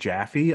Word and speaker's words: Jaffe: Uh, the original series creator and d- game director of the first game, uh, David Jaffe.